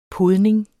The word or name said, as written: podning